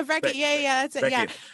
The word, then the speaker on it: Brekke: Brekke. Yeah, Brekke.